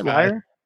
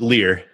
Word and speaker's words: Leier: For Taylor Leier, is that how you say his name, right? Leier? – From what I understand, Leier. – Leier, okay.